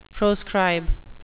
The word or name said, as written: proscribe